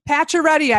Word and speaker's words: Pacioretty: Pacioretty, I pronounced it correctly, right, Tom? – That's correct, just the way it's spelled. – Like the hockey player, although it's spelled differently.